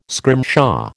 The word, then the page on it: scrimshaw: American English